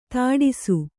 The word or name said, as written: ಧಾಡಸೀ